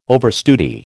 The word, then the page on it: overstudy: American English